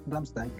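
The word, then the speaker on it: Rammstein: Ramstein, podríamos instalar un paralelo entre Ramstein. ¿Cómo? ¿La pronunciación está correcta? ¿Ramstein? Sí, sí, Ramstein.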